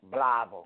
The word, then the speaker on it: Blytheville: Blytheville. – How do they pronounce it? – Blytheville.